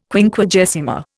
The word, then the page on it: Quinquagesima: Indian